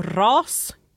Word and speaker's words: RaaS: Det förkortas eh, RAAS, så RAS kanske man uttalar det.